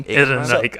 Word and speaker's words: Ehrenreich: Ehrenreich.